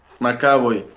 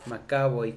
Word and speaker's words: McAvoy: ¿Cómo se dice su apellido? – Macaboy. – Macaboy, como Macaboy.